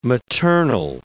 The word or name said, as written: maternal